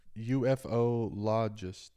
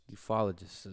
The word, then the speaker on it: ufologist: UFOologist. – Ufologist is how you pronounce that.